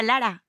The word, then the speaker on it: Lara: I really like the way that you say your name because it's so infectious that it makes all the other people say your name. People that might not attempt another dialect or accent, we all try to go for a Lara. – Yeah, Lara. – But I can't roll my R's.